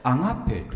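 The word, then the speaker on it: ア ガ ペ (ἀγάπη): これはね、ギリシャ語でアガペと言います。アガペ。で、アガペの愛ってのはよく神の愛とかって言われるんですね。で、神の愛は全員に平等ですよとか言う、嘘です。これね、ヴィンセントという人の釈義書があるんですが、アガペ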